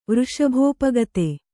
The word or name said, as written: ವೃಷಭೋಪಗತೆ